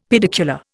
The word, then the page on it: pedicular: Indian